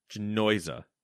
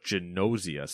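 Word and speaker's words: Gnosia: Genosia, Silent G. – That's what my first assumption was. Silent G. – See, that's what I went there, and then I was like, "But Genosia sounds pretty good."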